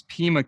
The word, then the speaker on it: Pima: But I see that the county that you tried it in was Pima County. Am I pronouncing that right? – Yes, that's correct.